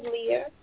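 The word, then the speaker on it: Leigha: So, my name is Leah.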